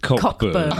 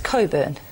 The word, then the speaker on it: Cockburn: Yeah, it's spelt that way. It's pronounced Coburn. – Okay, spelt Cockburn, pronounced Coburn. Okay. Yeah. Interesting. So the C and the K are silent.